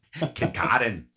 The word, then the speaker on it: Kincardine: I'd like to say Kincardine, but I hope I say it right. – Kincardine.